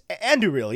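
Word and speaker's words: Andúril: they pronounce it in the movies, and I know how Rob Inglis pronounces it, but they're the same. It's like, it's Anduril, yeah.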